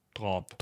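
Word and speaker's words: dráp: Und das ist eben dieses äh, Grinda Drap. – Drop. Drop wird das, glaube ich, ausgesprochen, ja.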